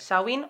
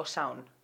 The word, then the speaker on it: Samhain: Bueno, a ver, hay dos, pronunciaciones, Samhain o Sound.